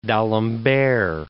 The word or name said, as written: Alembert, d'